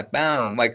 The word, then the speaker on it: Baum: Baum? Am I pronouncing it wrong for years? – Yeah, Baum, like a tree, Oh, okay.